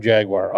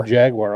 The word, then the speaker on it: Jaguar: The car company, when they say Jaguar, they go Jaguar. – Yeah, it's not that many syllables. Just the two.